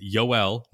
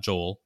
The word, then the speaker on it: Yoel: It's Yoel, not Joel. Yoel Piamps, not Payamps.